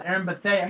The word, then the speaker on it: Erin Bethea: Folks, as actress Erin Bethea, I guess you got it right the first time, Joe. For once, you pronounced something correctly.